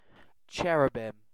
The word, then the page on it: cherubim: British English